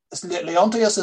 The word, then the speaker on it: Leontius: Sorry, I'm going to try to say his name right here. Lonitus? – That's a name now. – That is he an uprising against Justinian II? – Leontius is the man who leads the big.